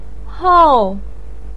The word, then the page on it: hou4: pinyin